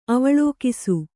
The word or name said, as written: ಅವಲೋಕಿಸು